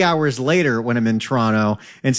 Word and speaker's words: Toronto: They don't say Toronto, they say Toronto. That's how it's done.